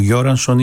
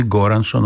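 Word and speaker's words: Göransson: ο Γιόρανσον ή Γκόρανσον όπως θέλετε είναι θέμα προφοράς ανάλογα βέβαια από πού το προφέρει, πώς το προφέρει κανείς, Σουηδός συνθέτης γεννημένος το 1984, υπολογίστε πόσο χρονών είναι αυτός ο τύπος.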